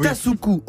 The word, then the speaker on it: Tasuku: Ils sont deux, Tsasushu pardon. Parce que ça, c'est la prononciation de Kyoto. c'est ah Tasuku Onjo et James Allison pour leur traitement des tumeurs cancéreuses par inhibition de la régulation immunitaire négative.